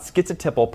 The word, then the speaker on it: schizotypal: Today I'll be talking about schizotypal personality. – Schizotypal. So I think he has the T in there.